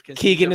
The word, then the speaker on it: Keegan: Keegan is how you pronounce. You didn't know how to pronounce Keegan.